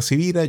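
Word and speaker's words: Sivira: Mi nombre es Carlos Javier Civira. Yo sé que mi apellido no es tan común, así que mi apellido se escribe con S de semana y con B de Victoria.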